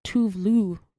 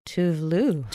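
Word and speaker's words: Tove Lo: So I actually have had Tovlo on repeat a little bit. – Someone told me that it's pronounced Tuvlu. Because she's Swedish, right? – Tuvlu.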